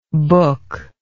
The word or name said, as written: booklike